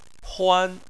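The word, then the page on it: huan: tone 1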